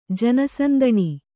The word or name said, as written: ಜನಸಂದಣಿ